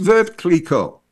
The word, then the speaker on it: Veuve Clicquot: The other little thing that people sometimes don't get exactly right is it's Veuve Clicquot. And that's the way the French pronounce it. For some reason, there's an EU there. So some people tend to way overpronounce the Veuve. And that's not the way they pronounce it at the Champagne House.